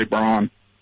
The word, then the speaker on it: Braun: Oh, there's a dude that lived here, Werner von Braun, or von Braun, they say, but I think it's pronounced Brown, but we all say Braun.